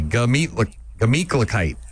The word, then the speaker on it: Gemütlichkeit: Here we go. Meat. – Meat. – Kite. Not a gla. Just G-A. Gemütlichkeit.